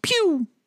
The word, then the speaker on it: Pugh: I think her name is actually pronounced Florence Pugh.